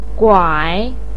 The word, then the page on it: guai: 3